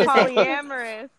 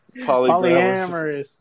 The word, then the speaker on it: polyamorous: How you pronounce that? Polygamore, what? Polyglamorous? – No. – What's polyamorous? – Polyamorous, yeah. – Polyamorous.